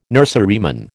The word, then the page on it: nurseryman: American English